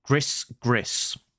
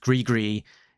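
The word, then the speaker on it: gris-gris: I'll say this as it's written, gris gris. So gris gris, pronounced gree gree, is Cajun, which means to curse someone, frequently used in jest.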